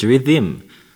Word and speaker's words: Riddim: R-I-D-D-I-M. When really it's Riddim. You see me, but Google, yes, Google, says a musical genre. So, this is what they say. Riddim is the Jamaican patois pronunciation of the word rhythm.